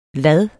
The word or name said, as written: lad